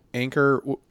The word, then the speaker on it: Anker: And for those not familiar with that brand, Anchor is not like... spelled like the boat anchor, but A-N-K-E-R.